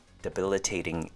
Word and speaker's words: debilitating: And shuns, it's your typical T-I-O-N sound that sounds like it's spelt S-H-U-N. Reparations. Next word, debilitating. Five syllables. De has a short U sound. Be has a short I sound. Le has a short U sound. Te sounds like hey again, but with a T instead of the H. And ting, typical ing ending sound. Debilitating.